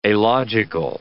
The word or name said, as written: alogical